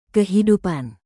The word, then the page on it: kehidupan: Indonesian